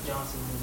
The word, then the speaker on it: Johnson: and you're Joel Johnson. My first name is spelled J-O-E-L. It's named J-O-H-N-S-O-N.